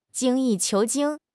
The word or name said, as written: 精益求精